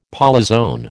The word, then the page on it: polyzoan: American English